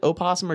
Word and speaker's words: opossum: But like P-A-W, not like a possum. – Right. – And opossum, do you pronounce it opossum or just say possum like a normal person? – Possum.